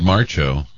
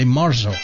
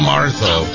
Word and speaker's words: Marzo: I just think Marcho. That's fine. – Yeah, but that's wrong. If you're going to do it in Spanish, it's Cinco de Marzo.